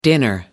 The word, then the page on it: dinner: American English